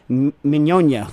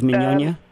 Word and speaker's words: Mignogna: Okay, I'm going to try to pronounce his name, Mignogna. What do you think, Nastasha? You speak Italian, Steve Mignogna? – I think so. I think that's fine. Yeah, that's good.